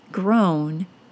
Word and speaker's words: grown: However, I think some English language learners don't trust that they are hearing a different pronunciation in words like grown, G-R-O-W-N, and known, K-N-O-W-N. Both of those words are spelled O-W, but pronounced with a long O sound, O, and not the OW sound, ow.